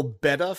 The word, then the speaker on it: betta: And there was, my wallpaper, long story short, is a like a virtual betta fish. Is that how you pronounce that? And it's just, yeah, that sounds right.